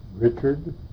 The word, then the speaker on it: Richard: Susan, S-U-S-A-N. – D-E-P-I-G-I-A-N. – Richard, R-I-C-H-A-R-D, DePiggin, D-E-P-I-G-I-A-N.